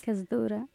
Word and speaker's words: Kasdura: und dann nehmen wir uns die Zeit, das nächste Lied zu suchen und im Anschluss nach Kasdora. Ich weiß nicht, ob die Aussprache richtig ist. – Kasdura. – Kasdora? – Ja, das auch Arabisch.